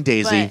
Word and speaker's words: Doese: Something Daisy. – But can I spell it out? – Sure. – Caralynn.Doese. – Okay. – And yes, that's pronounced Daisy.